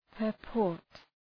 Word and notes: purport: pər’pɔ:rt

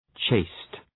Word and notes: chaste: tʃeıst

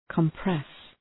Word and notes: compress: {kəm’pres}

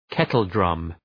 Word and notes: kettledrum: ‘ketəl,drʌm

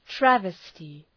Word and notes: travesty: ‘trævıstı

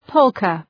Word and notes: polka: ‘pəʋlkə

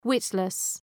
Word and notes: witless: ‘wıtlıs

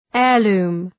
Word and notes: heirloom: ‘eər,lu:m

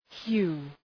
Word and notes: hue: hju:

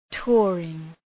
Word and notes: touring: ‘tʋərıŋ

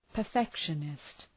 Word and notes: perfectionist: pər’fekʃənıst

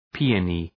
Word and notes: peony: ‘pi:ənı

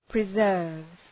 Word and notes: preserves: prı’zɜ:rvz

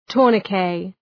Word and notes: tourniquet: ‘tʋərnı,keı

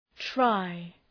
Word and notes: try: traı

